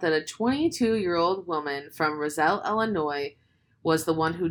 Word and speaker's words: Roselle: How do you say it? – I don't know. Roselle? – Okay. – Roselle? Roselle. – It's Roselle. I know it is.